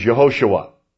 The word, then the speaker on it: Yehoshua: The J sound is always like a Y sound. It's not Jehovah, it's Yahweh, Y-A-H-W-E-H, the name of the Lord. Now, J-E-H, Jehosh, Hoshua, U-A. J-E-H-O-S-U-S-H, U-A. Pronounced Yehoshua. Now that means Jehovah is salvation.